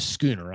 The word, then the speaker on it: Schooner: That is Schooner. I'm not mispronouncing Sooner, folks. I know what I'm doing here.